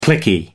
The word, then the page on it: cliquey: American English